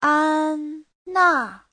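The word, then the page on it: 安娜: an1na4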